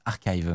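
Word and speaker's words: arXiv: Apparemment, ah, ça se prononce archive, Mathieu.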